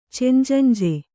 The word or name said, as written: ಚೆಂಜಂಜೆ